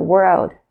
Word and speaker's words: world: 大家要特别注意一下这个 world。的发音并不是非常好发，有很多同学呢忽略了这个 l 的这个翘舌音呢，就会变成 word 这个字。那 word 会变成是字这个字，就没有这个 l 在里面，就不能当做世界来解释。